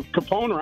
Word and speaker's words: Capone: Well, I mean, so Capone rhymes with phone, no? – Yeah, just like dog and bone.